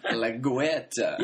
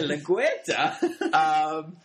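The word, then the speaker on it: LaGuerta: La Guerta, am I saying that right? – I don't think it's wrong so much as you seem to just be enjoying pronouncing it. There's a lot of enunciation. La Guerta. – Yeah, La Guerta.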